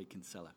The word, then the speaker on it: Kinsella: So it gets usually when I introduce myself, I say Kinsella.